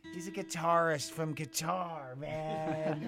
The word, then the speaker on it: Qatar: Qatar, man. – Qatar rhymes with guitar. – You know what? Actually, if you say it fast, it sounds like guitar.